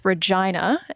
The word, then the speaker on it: Regina: And then in my 20s, I moved to the middle of the country into a place called Regina. And yes, that is how you say it.